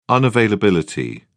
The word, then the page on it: unavailability: British English